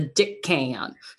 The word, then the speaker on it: Dickon: In fact, I mispronounced it in my audition for it, right? Called him Deacon because I'm like, Daikon, maybe Deacon, Dickon.